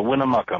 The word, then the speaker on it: Winnemucca: It's uh, Winnemucca, Mark.